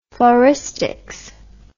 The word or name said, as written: floristics